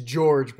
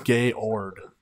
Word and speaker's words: Georg: It's George, but German, so Georg. – Oh, Georg, yeah. Okay. – That's how you pronounce it. I thought you said Gayord.